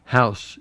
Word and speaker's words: Howse: How do you say his last name? House? – House. – H-O-W-S-E. – H-O-W-S-E.